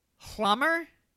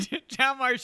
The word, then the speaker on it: Hjalmar: I'm going to spell it for you. H-J-A-L-M-A-R. Hlammer? Hlammerstadt? I'm saying Hlammerstadt. – Jalmar. – Jalmar.